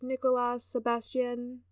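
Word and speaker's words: Nicolas Sébastien: Oh, it's French, so it's Nicolas Sebastian.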